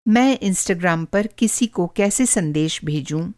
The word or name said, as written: इंस्टाग्राम